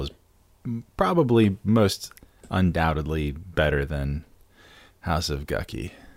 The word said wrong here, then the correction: Gucci: It's actually pronounced Gucci. I know.